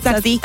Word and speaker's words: Tzatziki: Tzatziki.